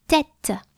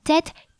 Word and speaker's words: tête: The head is la tête. La tête, T-E, accent circumflex, T-E, la tête.